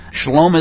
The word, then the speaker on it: shalom: You know, it's spelled with a sheen, lamed, and then a vav with the little O dot over it, so it's a long O sound, and then an ending mem. So shalom.